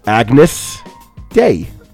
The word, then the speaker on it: Agnus Dei: This is, I believe, their sophomore album, their second album, and this song is pronounced Agnus Dei, and I believe that is Latin for Day of Agnes.